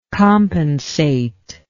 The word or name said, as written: compensatingly